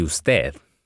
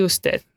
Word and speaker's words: usted: And I imagine then, ¿y usted? If I'm being formal. – ¿Y usted? – ¿Y usted? – Mm-hmm. So try to emphasize the last syllable, ¿y usted? – ¿Y usted?